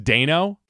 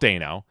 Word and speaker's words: Dano: And I said maybe the Court of Owls, which did not happen, but Paul Dano, which, by the way, you know it's pronounced Dano? – I don't like that. I don't care for that. – Because I've been watching interviews, and he's like, Dano. And he calls himself Dano.